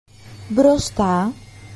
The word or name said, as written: μπροστά